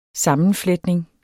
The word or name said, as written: sammenfletning